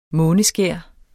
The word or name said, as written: måneskær